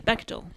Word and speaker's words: Bechdel: Bechdel. – Bechdel.